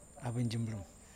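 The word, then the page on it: abinjinrum: Kundiman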